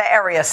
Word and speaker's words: Arias: Yeah, Tessa Arias is a baker and the founder of Handle the Heat.